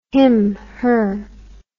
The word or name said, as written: him her